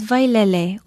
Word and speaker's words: Wailele: Aloha, I'm Lilani Poliahu of Hawaii Public Radio, and our Hawaiian word of the day is vailele, or waterfall. Literally, vailele means leaping water. Vailele is spelled W-A-I-L-E-L-E,